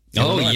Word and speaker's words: Illinois: Representing you in Illinois. I said it right. – Illinois.